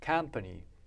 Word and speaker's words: company: アクセントは、曖昧母音にはアクセント来ませんので、こう 2 音節なんですけど、あるいは 3 音節。アクセント書かなくてもどこにあるか分かります。ここですね。カンパニー。はい。 – Company、カンパニー。